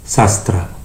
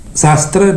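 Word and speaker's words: śāstra: Nah, senjata dalam bahasa Sanskertanya adalah sastra. Senjata dalam bahasa Sanskertanya adalah sastra. Namun bila kata sastra pengucapannya diubah sedikit, yaitu suku kata pertama sas diucapkan lebih panjang sehingga menjadi sastra, maka lain lagi artinya.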